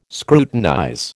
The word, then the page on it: scrutinize: American English